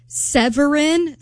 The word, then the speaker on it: Severin: It was like Severin. Severin, like, ooh, like you, like Severance, love, but without the s.